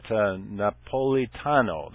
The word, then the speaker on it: Napolitano: Here's Janet Napolitano, that's how you pronounce the name.